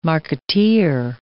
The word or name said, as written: marketeer